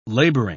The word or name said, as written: laboring